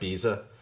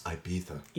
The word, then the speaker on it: Ibiza: And somebody on a podcast mispronounced Ibiza. – Oh. – Like Ibiza. It's pronounced Ibiza.